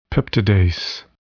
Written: peptidase

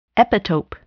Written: epitope

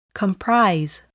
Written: comprise

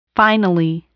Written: finally

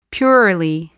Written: purely

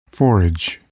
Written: forage

